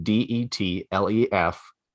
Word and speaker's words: Detlef: His name is spelled D E T L E F that's his first name, Detlef.